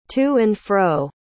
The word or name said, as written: to and fro